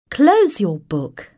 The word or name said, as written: Close your book.